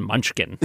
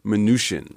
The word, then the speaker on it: Mnuchin: And I can tell you that officially the pronunciation is not Mnuchin, it's Mnuchin. – Like minutia. – Mm. As a wonderful email to Kai Risdahl once put it, the CH is soft as in champagne or charlatan.